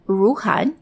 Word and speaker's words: Ruhnn: It's called Ruhan, that's spelled R-U-H-N-N, and it's just filed for IPO a few weeks ago here in the US on the NASDAQ.